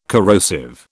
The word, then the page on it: corrosive: British English